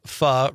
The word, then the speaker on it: pho: Eric, pho rhymes with duh, is a Vietnamese soup, includes rice noodles, shaved meat, other meats, and veggies can go into it.